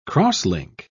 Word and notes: cross-link: British English

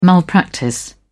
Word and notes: malpractice: British English

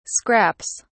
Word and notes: scraps: British English

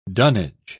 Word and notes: dunnage: British English

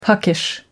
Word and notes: puckish: American English

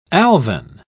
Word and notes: alvine: British English

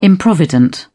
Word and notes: improvident: British English

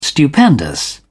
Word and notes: stupendous: British English

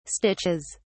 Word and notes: stitches: British English

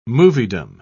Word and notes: moviedom: British English

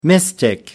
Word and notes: mystic: American English

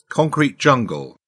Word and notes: concrete jungle: British English

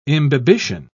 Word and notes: imbibition: British English

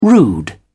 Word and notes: rude: British English

